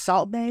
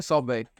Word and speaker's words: Salt Bae: Um, so on your podcast, you know exactly what episode you did a podcast about the Turkish step I think it's Salt Bae is how you pronounce it. – Oh yeah, Salt Bae. Yeah.